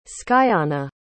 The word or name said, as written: skyanna